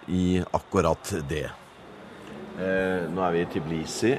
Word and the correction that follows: Tbilisi: Eh, sier jeg det riktig? – Tiblisi. Tbilisi. – Tbilisi. T-b-i. – Ja. – Uten i mellom de to ja, men, konsonantene. Sånn hvordan? T-b-i.